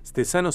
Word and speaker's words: Stezano: Stesano se escribe con S como consonante inicial, T-E-Z-A-N-O.